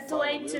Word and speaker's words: Tuenti: Uma rede social da Espanha que chama Tuente. Não sei como é que é. Acho que a pronúncia, a pronúncia deve ser do jeito que. – É Tuente mesmo.